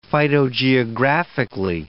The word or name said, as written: phytogeographically